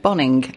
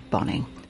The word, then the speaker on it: Bonynge: I always want to say Boninge. I know I say it every time, but whenever I see his name, it's spelt B-O-N-Y-N-G-E. Well, I'm sorry, but that's an Nge sound, isn't it? Boninge. Anyway, he says Boninge. I say Boninge, but I suppose I ought not to be rude to him, Boninge.